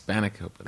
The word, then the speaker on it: spanakopita: Yeah, Isn't that how you say it, or no? – How'd you say it? – Spinacopita. – I think it's spanakopita.